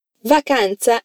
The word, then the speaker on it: vacanza: Atenção à pronúncia do tsa, de vacanza. É como se tivesse um TS ali. Então, como eu diria vamos de férias?